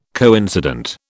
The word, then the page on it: coincident: British English